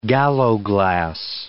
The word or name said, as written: gallowglass